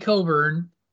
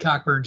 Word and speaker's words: Cockburn: So Kofi Coburn, who changed his pronunciation to Coburn after people made Cockburn jokes.